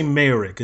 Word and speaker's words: Mayerick: I always say Mayrick. Is that the pro- correct way to pronounce it? – Yeah, it is just phonetically the way it's spelled. Yeah.